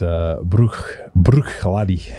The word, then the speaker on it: Bruichladdich: It's a very difficult one to pronounce. It's Bruichladdich. – Bruichladdich. – Yeah. It's not no ich on the end, just laddie.